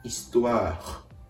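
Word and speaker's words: histoire: このうがいをするようなという仕草にハヒフヘホのフを足してイストワーイストワーと最後に発音を止めてあげるように発音するのがポイントです。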